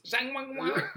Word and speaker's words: Zhang Weili: So, the co-main event, Carla Esparza versus Zhang Weili. I think I said that right. Zhang Weili. – It's Zhang Weili.